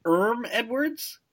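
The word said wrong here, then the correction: Herm Edwards: without an H? – Maybe I did. – I'm pretty sure you did. – I've never heard that pronunciation. I believe it's Herm.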